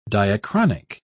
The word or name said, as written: diachronic